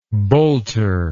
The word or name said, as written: boulter